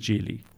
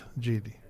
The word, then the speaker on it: Geely: So it is Geely. – That's how the Chinese pronounce it, Geely.